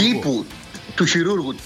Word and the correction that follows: χειρουργού: τύπου κρόκς, όχι κρόκς, σκλαβερόκς. – Ό,τι τονίζεται στην παραλίγουσα είναι το αρνητικό. – Του χειρουργού, του χειρουργού, συγγνώμη.